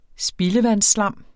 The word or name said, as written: spildevandsslam